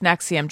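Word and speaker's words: NXIVM: But it's the cult is spelled like NX. It's all Roman numerals. – Right. – But it is pronounced Nexium, Jordan.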